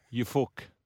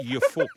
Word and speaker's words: Ufuk: You fuck. Kids again. Sorry, parents. – Is that Conor McGregor? – Look at this guy. Who you fuck is this guy? No, it's pronounced your fuck.